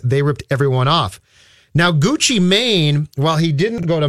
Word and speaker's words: Gucci Mane: So now the Strib found out that rapper is it Gucci Mane Yep, there you go. Nailed it.